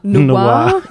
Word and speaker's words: noir: But do you have to pronounce it in the American way? Do you have to say noir? – Noir. Yeah. Noir.